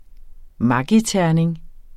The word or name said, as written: maggiterning